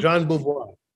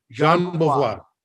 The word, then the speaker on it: Jean Beauvoir: John, John, Jean Beauvoir. – Jean Beauvoir.